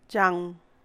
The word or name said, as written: zhangw